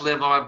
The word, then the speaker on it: LivOn: It's pronounced Live On, but there's no E.